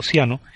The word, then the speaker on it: Ciano: Si prestáis atención, podréis escuchar claramente los nombres de Mussolini y Chiano, pronunciado Ciano, y hasta el de Napoleón, en una graciosa comparación del Regio Esercito con las tropas de Napoleón.